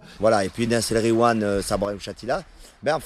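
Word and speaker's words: Nass El Ghiwane: Nas El Gwiam. – Nas El Gwiam, voilà. – Et quand il y a GH, tu dis re. Tu fais comme si c'était un R.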